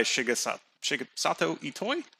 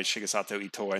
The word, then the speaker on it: Shigesato Itoi: So it's just, you know, directed by Shigesato Itoi.